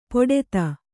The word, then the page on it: ಪೊಡೆತ: poḍeta